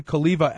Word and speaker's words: Cholewa: It's Jason Kaliva, and the V is actually a W. It's pronounced V.